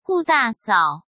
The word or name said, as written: Gu Dasao